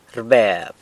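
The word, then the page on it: rbeb: San Lucas Quiaviní Zapotec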